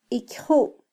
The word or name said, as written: ikyju